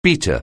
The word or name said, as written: beater